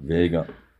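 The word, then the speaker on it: Veiga: Ist das richtig ausgesprochen? W-V-E-I-G-A. Vega, Vega, würde ich ja, sagen. Vega.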